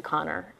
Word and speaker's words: Kanner: and the other is Leo Kanner, um, or I should say Connor, um, is how he pronounces it.